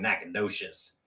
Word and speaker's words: Nacogdoches: It's pronounced fucking Nacogdoches.